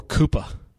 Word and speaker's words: Cooper: It's spelled Cooper like Amari. – It's really more Koopa.